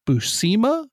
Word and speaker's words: Buscema: What about the artist, John Buscema? Is that how you say it? Or Buscema? You always correct me, so I figure you know. – I think you had it right the first time.